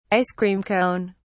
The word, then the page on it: ice-cream cone: British English